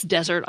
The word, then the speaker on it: desert: The arid place desert, on the other hand, has one S and the first syllable is stressed. The noun that means what you deserve, spelled D-E-S-E-R-T with one S, confusingly has the second syllable stressed, just like the word that refers to cake or cookies.